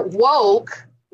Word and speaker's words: WOKE: Yolanda, I'd like for you to tell the good people listening about women of color or woke. Right? It's an acronym, Women of Color. – Long O.